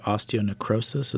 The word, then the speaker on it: osteonecrosis: You have a degenerative disease called osteonecrosis. Is that how you say it? – Yep. It can also be called avascular necrosis.